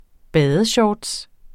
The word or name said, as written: badeshorts